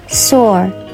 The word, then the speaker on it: soar: soar 这个词还可以发音成 saw，saw 就是这个 r 的音可发可不发。如果是美式的，那就是一定要把 r 的音发出来；英式则可以发也可以不发。